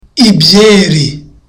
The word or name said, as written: ibyeri